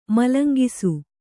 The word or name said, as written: ಮಲಂಗಿಸು